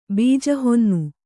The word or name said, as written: ಬೀಜಹೊನ್ನು